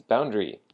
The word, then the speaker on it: boundary: Because it means boundary. So the word boundary has the emphasis on the first syllable, the boundary, boundary.